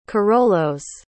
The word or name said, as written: carolos